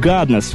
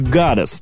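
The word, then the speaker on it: goddess: Она поет «гаднес» вместо «гадес» — «богиня».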